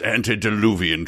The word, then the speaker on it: antediluvian: Your inability to pronounce antediluvian troubles me. Try it again, young lady. – Tickle in the throat, I said. – Certainly. – There was an antediluvian monster at this party.